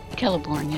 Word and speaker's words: Celeborn: Celeborn. – Celeborn, yes.